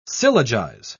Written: syllogize

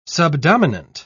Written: subdominant

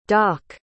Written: dak

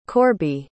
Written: Corby

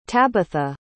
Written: tabatha

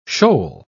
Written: shoal

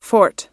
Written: forte

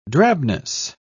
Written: drabness

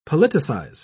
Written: politicize

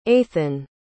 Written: aithan

